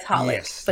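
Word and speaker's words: xxxHOLiC: It was like XXX Holic, but it was like, but it's pronounced Holic.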